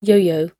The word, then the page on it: yo-yo: British English